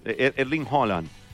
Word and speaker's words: Haaland: Es entre A y O. – ¿Ve que se le pronuncia así? – Es que le dicen Haaland o Haaland. No. Ellos no utilizan, no es como el español, que hay vocales cerradas o abiertas. Ojo, eso es muy distinto. O sea, la A. No, ellos, Erling Haaland, como entre O y A.